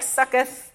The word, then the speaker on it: Succoth: And it's spelled S-U-C-C-O-T-H. So it looks like Sukkoth. And basically, a booth or a succoth or a succa, however you want to say it, it's basically just a temporary shelter, and you're supposed to keep it really flimsy.